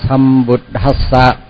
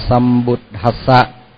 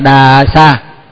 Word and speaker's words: sambuddhassa: Nhưng mà nhớ là dù đọc cách nào nữa thì cũng đừng đọc là đà sa.